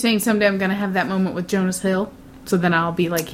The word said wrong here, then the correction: Jonah Hill: Jonah Hill. – Get his name right. He's not Jonas a member of the Jonas Brothers.